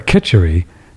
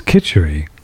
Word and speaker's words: khichdi: Kachari. That's right. K-I-T. C-H-A-R-I. Or Kitchery it's the actual, the best pronunciation is khichdi.